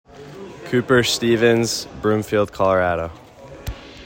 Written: Cooper Stephens

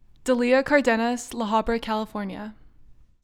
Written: Daleah Cardenas

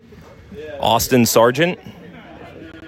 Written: Austin Sargent

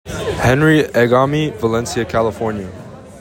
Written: Henry Egami

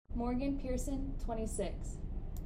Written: Morgan Pearson

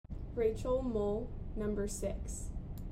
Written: Rachel Mull

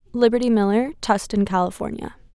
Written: Liberty Miller

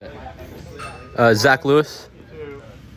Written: Zach Lewis

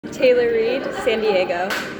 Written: Taylor Reed

